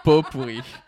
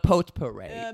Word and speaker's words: potpourri: Potpourri. You smell like potpourri. That's how we pronounce it, by the way. – Oh, boy. – It's not potpourri.